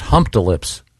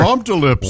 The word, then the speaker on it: Humptulips: I wonder if they pronounce it. Maybe it's pronounced Hump to Lips. – Boy, Hump to Lips is almost more offensive. – Yeah. – I like it. – Yeah. But yeah, it's HUMPTULIPS.